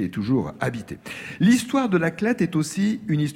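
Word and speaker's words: La Clayette: L'Aclette, ça s'écrit Cléette, mais on prononce L'Aclette, donc.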